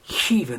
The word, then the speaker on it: Khiva: Now, I'm pronouncing it Khiva, not because I have a cold, but because it's one of these places spelt K-H-I-V-A.